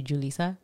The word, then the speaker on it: Julissa: It's kind of like when they say about your name, when they're like, oh, like for me, they call me like Jalissa, but it's actually Julissa.